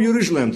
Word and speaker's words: Jeruzalem: Dan krijgt Nehemia toestemming om uit de ballingschap terug te keren en om Jeruzalem te bouwen, met de klemtoon op het laatste.